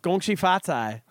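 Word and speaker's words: Gongxi Fatai (恭喜发财): Well, it depends on where you are. – Well, how do you say it in Chinese? Oh, yeah, where you are. – Gongxi Fatai is in China. – Gongxi Fatai. – I think Gong Hei Fat Choi is in Hong.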